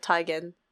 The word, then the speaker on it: Teigen: pronounced like Teigen.